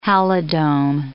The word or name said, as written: halidome